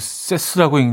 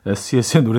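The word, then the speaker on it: S.E.S.: SES e 처음 나왔을 때 이거 그 세스라고 읽는 친구들도 있었는데 SES의 노래 듣고요.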